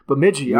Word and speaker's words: Bemidji: Bemidji. All right, I was right. All right, I was good. – B-E-M-I-D-J-I.